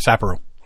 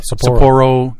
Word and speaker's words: Sapporo: Yeah, that's how you put Sapporo. That's how she pronounces it, so she's got to be right. – So it's not Sapporo. – No, I think that's the English-sized version of the beer, Sapporo.